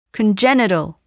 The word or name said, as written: congenital